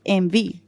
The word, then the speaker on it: enby: O sea, envy lo escriben en español como E-N-B-Y, ¿cierto? Sí. Pero eh, realmente se escribe NB significa en inglés non-binary. Entonces, ah. cuando tú lo pronuncias es envy.